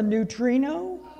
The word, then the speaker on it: neutrino: Never heard of a neutrino? Neutrino. That's how it's pronounced.